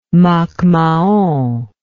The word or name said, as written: macmahon